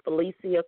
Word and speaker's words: Felecia: I can give you my Facebook page, which is my first name, last name, Felicia Christian. That's spelled F-E-L-E-C-I-A, Christian, C-H-R-I-S-T-I-A-N.